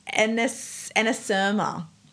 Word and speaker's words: anasyrma: It's called anasyrma. So it's spelled A-N-A-S-Y-R-M-A, anasyrma.